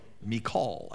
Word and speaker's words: Michal: Verse 20, now Saul's daughter Michal, that would probably be the way to pronounce it if you look at it in the Hebrew. We say Michal, but Michal. Michal, actually, the emphasis on the second syllable.